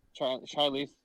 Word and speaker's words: Charlize: Charlize.